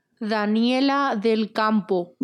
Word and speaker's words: Daniela Del Campo: Daniela Del Campo.